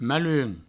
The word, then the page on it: malhum: maiun